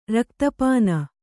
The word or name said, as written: ರಕ್ತಪಾನ